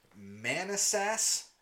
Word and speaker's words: Manassas: This happened in Manassas, Virginia. I'm going to just spell it for you. M-A-N-A-S-S-A-S.